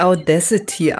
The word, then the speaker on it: Audacity: Genau, man spricht es richtig Audacity aus.